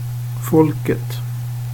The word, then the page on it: folket: svenska